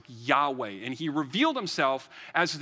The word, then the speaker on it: Yahweh: Anytime it's all caps Lord, it means the name Yahweh, which that's how we believe you pronounce it.